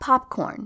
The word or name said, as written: pop corn